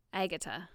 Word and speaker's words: Agutter: I can never get used to anybody calling her Agutter, because I've only ever heard English people pronounce her name. – How do they pronounce it? – Agatha. – Oh, they just say Agatha.